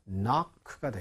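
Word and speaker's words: knock: 앞쪽에 K가 발음이 있어요, 없어요? 이것은 발음이 없습니다. 그래서 우리가 평상시에 한국말로도 노크한다, 이렇게 얘기하잖아요. 그게 바로 knock가 되겠어요.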